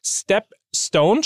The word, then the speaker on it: Stepstone: Stepstone schreibt man S-T-E-P und dann Stone wie Stein, äh, wie Stein in Englisch.